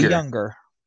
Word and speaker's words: Junger: According to the book Tribe, written by Sebastian Junger, hopefully I didn't butcher his name. – I think it's actually Junger. – Thank you. – It's a lot of those languages, the J and the Y.